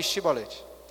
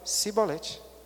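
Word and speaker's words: chibolete (שיבולת): E quando ele falava que não era de Efraim, então falava assim, beleza, então fala aí chibolete. Por alguma questão de pronúncia, eles não conseguiam falar chibolete e se entregavam falando cibolete.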